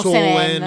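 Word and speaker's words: Samhain: Ecco, si scrive Samain, si pronuncia in molti modi diversi. – Ecco, noi diremo Soen, Semen. Semen, ok.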